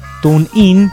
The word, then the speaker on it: TuneIn: TuneIn, pronunciándolo bien, pues se enojan, sino también.